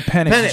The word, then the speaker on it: Penix: So his name is Michael Penix Jr. Penix. But it's spelled P E N I X.